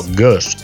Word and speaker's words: Gueuze: Las GUEUZE o Gueuze, que se pronuncia algo así como Gus o GUS.